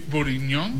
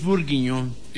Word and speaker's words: bourguignon: Beef bourguignon. – Beef bourguignon.